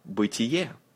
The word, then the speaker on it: Бытие: First book, Бытие. Бытие. Say, тие. Бытие. Stress is on the last vowel. Бытие sounds like a word to beat in English.